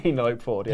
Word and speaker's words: Penelope: Penelope, is that how you say it? – Penelope Ford, yes.